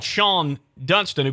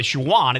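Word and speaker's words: Shawon: Yeah, you said you roomed with what we call Sean Dunston, who played with the Cubs, but you said his name is really Shawan. It's spelled Shawan.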